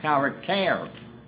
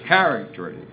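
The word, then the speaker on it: character (χαρακτήρ): The only difference in the pronunciation, if you pronounce it in its original setting, the accent's on the last syllable, character. Now, the English is character, accent on the first syllable, but it's spelt exactly the same way.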